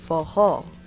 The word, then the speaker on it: forró: They called these dances for all, which Portuguese speakers pronounced as forró.